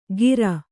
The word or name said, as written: ಕೀರ್